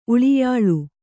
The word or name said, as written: ಉಳಿಯಾಳು